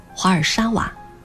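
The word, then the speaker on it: Warszawa: 华沙在波兰语中念作华尔沙瓦，这个名字是为了纪念一名叫华尔西和沙娃的恋人，他们冲破重重阻挠，最终结为夫妻的勇敢精神。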